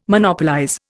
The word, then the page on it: Monopolize: Indian